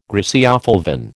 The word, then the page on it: griseofulvin: American English